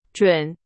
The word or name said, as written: 准